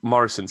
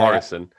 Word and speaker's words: Morison: Right, moving on to the current manager, Steve Morrison. See how I'm pronouncing that, Tom? Yeah, Morrison.